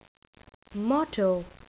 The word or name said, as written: motto